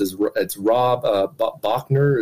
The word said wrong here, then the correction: Bauer: is that it? – Bauer, Rob Bauer.